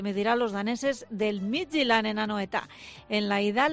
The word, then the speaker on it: Midtjylland: Eguerdión, lo primero que te digo es felicitarte porque qué bien has pronunciado Midtjylland.